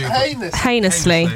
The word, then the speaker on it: heinous: Heinously. – Yeah. – A heinous crime. Heinous crime. – Thank you. – Heinous rhymes with Venus.